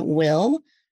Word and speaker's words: Whil: We also have Will, which is an app. It's spelled W-H-I-L.